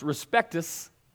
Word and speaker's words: respectus: So, respect, in Latin, this is what the Latin means. It's pronounced respectus, and it means to look at something over and over and over.